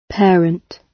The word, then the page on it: parent: British English